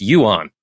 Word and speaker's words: yuan: Yeah, I also looked up the pronunciation. It's yuan, the Chinese yuan.